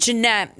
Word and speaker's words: Ginette: The reason I named Jeanette is because there's a merengue that's called Jeanette, the way my name is spelled, G-I-N-E-T-T-E.